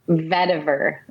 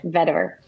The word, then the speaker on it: vetiver: Vetiver. V E T mm-hmm. I V E R is um, is how you spell it. Okay. Vetiver.